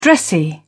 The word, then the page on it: dressy: British English